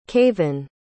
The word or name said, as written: kavan